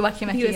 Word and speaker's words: Weki Meki: Yeah, Weki Meki.